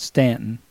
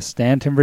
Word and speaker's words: Staunton: Now, that's actually spelled Staunton, but here in the country, we call it Stanton. I guess we're just country folk here, and that's how it's pronounced here in Virginia, Stanton, Virginia.